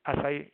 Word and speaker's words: acai: Is it acai? How do you pronounce acai? Acai bowls, yes.